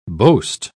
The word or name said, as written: boast